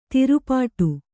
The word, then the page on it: ತಿರುಪಾಟ್ಟು: tirupāṭṭu